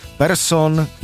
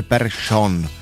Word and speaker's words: Persson: A sice, že čeští komentátoři začali vyslovovat švédská jména, tak jak je vyslovují Švédi. To znamená, že Ekman Larsson je najednou Ekman Larsson. Persson je Persson.